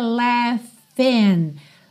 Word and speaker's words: laughing: Então, I still hear your voice in the traffic, we're laughing. Laughing, não se assuste com a escrita dessa palavra, porque a escrita é L-A-U-G-H-I-N-G, mas a pronúncia é laughing. Esse GH do meio vai soar como um F, f-f-f, laughing, tá?